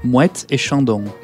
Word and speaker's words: Moët et Chandon: Moët et Chandon.